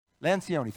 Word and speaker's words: Lencioni: and they were talking about this from Lencioni. I think you said that right. Lencioni, thank you.